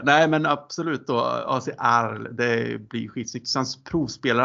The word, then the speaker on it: Arles: Alltså det står AC, ja, det, står, det stavas A-R-L-E-S. Arl.